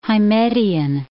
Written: Hymettian

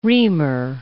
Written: reamer

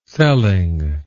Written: selling

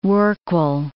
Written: rorqual

